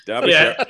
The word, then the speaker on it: Derbyshire: Is it's not Derbyshire. I'm sorry about this. It's Derbyshire. – No, Derbyshire. – Yeah, Derbyshire. Yeah, Derbyshire. There's no Shire. Oh. Derbyshire. Yes. – Yeah. – Shire is for hobbits.